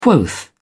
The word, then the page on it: quoth: British English